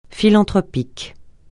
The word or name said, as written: philanthropique